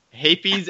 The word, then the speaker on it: Hapes: Hapes. – I will not say that. – It's just like you're yelling hippies in a weird accent. – Hapes and Isildur.